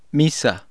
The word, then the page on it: misa: Spanish